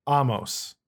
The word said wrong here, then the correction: Omos: Omos? Are you talking about Omos? – God, I'm so bad at that every time. I phonetically try to pronounce it every single time, and I cannot get it in my head that's Omos.